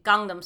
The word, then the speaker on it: Gangnam: I just didn't even realize that, because the very first link that I clicked was Gangnam Style. Is that how you say it? – Yeah, Gangnam Style.